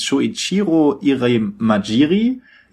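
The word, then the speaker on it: Shoichiro Irimajiri: 1998, also nachdem der Saturn schon 3 Jahre raus war, also Anfang 98, hat der neue Präsident von Sega, der hieß Shuichiro Irimajiri, Ivo, du kannst es auch richtig aussprechen, habe ich das richtig gesagt? – Ja, halbwegs.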